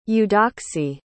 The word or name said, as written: eudoxie